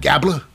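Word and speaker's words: Gable: And with Otis, is of course, it's the Alpha Academy of Chad Gabler. Rhymes with Tabla, Maxine Dupree, and Akira Mimezawa, because he's a meme right now, versus Shinsuke Nakamura.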